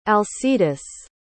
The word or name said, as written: alcides